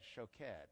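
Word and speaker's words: shoked (שֹׁקֵד): The word for watching is also the same three Hebrew letters. It's just pronounced with an O sound instead, shoked.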